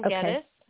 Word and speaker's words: Geddes: It's under Helen Geddes. My last name is spelled G-E-D-D-E-S.